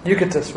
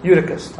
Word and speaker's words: Eutychus: Eutychus, right? Eutychus. I said that wrong. Eutychus. There we go.